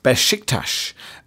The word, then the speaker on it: Beşiktaş: The S with a, is it a cedilla at the bottom? – Mm-hmm. – Makes a sound, so it should be Beşiktaş, with even stress on each syllable.